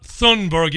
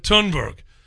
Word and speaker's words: Thunberg: It's not Thunberg. You don't pronounce the H. Took me a while to cotton on to that. It's Greta Thunberg.